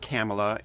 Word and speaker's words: Kamala: Kamala Harris is she said it's pronounced like comma with a la at the end, so it's Kamala.